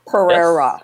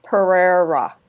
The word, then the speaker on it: Pereira: Pretty close. Pereira. Yes. Pereira.